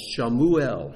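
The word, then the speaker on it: Shemuel (שמואל): Samuel is pronounced Shemuel, Shamu, God hears.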